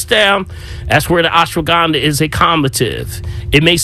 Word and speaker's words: ashwagandha: It's called ashwagandha.